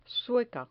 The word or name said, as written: sueca